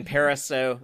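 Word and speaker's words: Parisot: I listened to an interview with the director, Dean Pariseau. That's how I know the T is silent.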